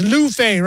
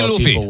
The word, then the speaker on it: Luffy: It's Luffy, right? Monkey D. Luffy. – I'm watching the game now. – Monkey D. – Luffy.